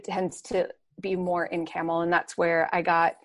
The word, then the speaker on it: Campbell: And so we have a fairly large Greek speaking population, especially in Camel schools, which is spelled actually like Campbell, like Campbell soups, but it's pronounced Camel.